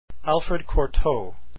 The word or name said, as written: cortot